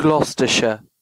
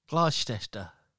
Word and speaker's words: Gloucestershire: Gloucestershire. – That's how you pronounce it. – What is it? – Gloucestershire. – Gloucester. Yeah. – Gloucester.